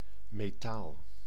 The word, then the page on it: metaal: /me.ˈtaːl/